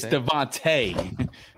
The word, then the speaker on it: DeVonta: It's Devontae.